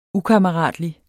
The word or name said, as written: UKAMMERATLIGT